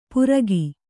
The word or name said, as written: ಪುರಗಿ